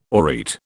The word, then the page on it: aureate: British English